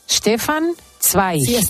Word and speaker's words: Stefan Zweig: Stefan Zweig.